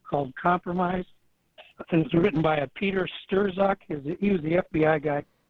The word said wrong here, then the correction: Strzok: Oh, Peter Strzok. – Yeah. – Is that how it's pronounced? – Yeah, I believe so.